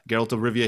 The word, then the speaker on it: Geralt of Rivia: Geralt of Rivia? Is that how you say it? – Yeah, Geralt of Rivia.